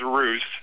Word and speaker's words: Roos: R-O-O-S is Roos. And that's fine. I have R-U-S-S. – Yeah. – In front of me. And then it says... And then it says it rhymes with goose.